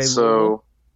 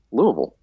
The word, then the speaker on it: Louisville: Wait, how did you say Louisville? Louisville. – Mm-mm. That's not how you say it. – Louisville. – Mm-mm. – How are you supposed to say it? – Louisville. Like, you have to have, like, it has to sound like you're really dragging it out, that you don't want to go there.